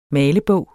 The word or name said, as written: MALEBOG